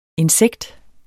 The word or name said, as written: insekt